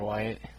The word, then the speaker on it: Wyatt: Griffin Tyler Wyatt. – Griffin Tyler Wyatt. Wyatt. Spell that last name. – W-Y-A-T-T.